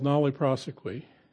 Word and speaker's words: nolle prosequi: In law, there's a phrase called nolle prosequi, and that's how my brother says to pronounce it. Don't try to pronounce it like the Latin it really is. The judge will look at you and maybe throw you out of the courtroom.